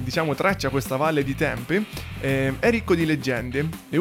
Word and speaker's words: Tempe: Allora, innanzitutto si scrive Tempi, ma si legge Tempe con la E finale.